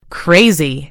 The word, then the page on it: crazy: British English